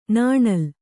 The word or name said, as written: ನಾಣಿಲಿ